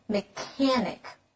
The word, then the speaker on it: mechanic: Yes, it is spelled with a C-H, but the sound we make is a K sound, mechanic.